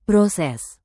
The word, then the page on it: proses: indonesian